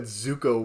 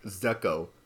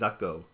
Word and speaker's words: Zuko: I'm really surprised that Zuko wasn't like Zuko. Like, he survived the culling pronunciation. Say that again. – I'm really surprised that Zuko wasn't pronounced Zucko.